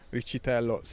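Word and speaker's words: Riccitello: I'm going to pronounce it alla italiana because it is an Italian name. Although I'm led to believe that Matthew Ricciatello sometimes pronounces it Ricciatello.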